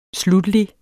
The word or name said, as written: sluttelig